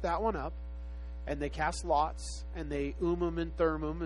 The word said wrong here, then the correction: Urim: And how do you say that again? The Ummim and Thummim. Thummim. The Urim and Thummim.